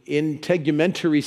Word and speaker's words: integumentary: And I think I finally know how to pronounce that first one, integumentary system.